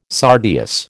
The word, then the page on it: sardius: American English